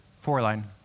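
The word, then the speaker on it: Fräulein: Fraulein.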